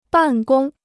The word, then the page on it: 办公: bàn gōng